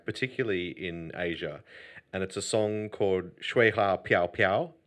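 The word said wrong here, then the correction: Xue Hua Piao Piao (雪花飘飘): Is it Xue Hua Piao Piao? – Yeah, that's the one.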